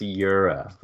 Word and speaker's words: Jura: It's Jura, the soft J.